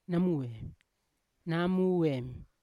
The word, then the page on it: namuem: Yawa